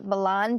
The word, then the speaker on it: Milan: So um, Milan, Ohio, which I am going to call it Milan because that is the correct pronunciation, happens to be the birthplace of Thomas Edison.